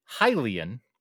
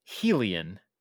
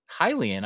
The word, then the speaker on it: Hylian: I have been told that I am mispronouncing the word Hylian. – You are. – By pronouncing it Helian.